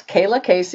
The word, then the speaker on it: Kaylaa Kayce: So introducing myself, yes, Kaylaa Kayce is how you pronounce my name. Spelled a little odd, but it's all good.